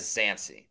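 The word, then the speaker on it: Dzansi: Is it Zancy? – Yeah, see, I am looking at it as Zancy. Just the B is silent.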